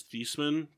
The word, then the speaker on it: Theismann: Joe Theismann's last name is actually pronounced as Theismann, but he changed it to Theismann before his senior year at Notre Dame because it rhymed with Heisman.